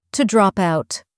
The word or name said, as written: to drop out